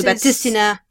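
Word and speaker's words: Baptistina: It's called the Batista or Batistina. It's called the Batistina.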